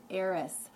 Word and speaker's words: Eris: And in today's podcast, I'm going to talk about the dwarf planet Eris. That is spelled E-R-I-S. And I know it sounds like Aries, so I just wanted to differentiate between the two, because this dwarf planet Eris is in Aries, and she is an important player here in the cosmos at this time.